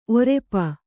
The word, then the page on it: ಒರಪು: orapu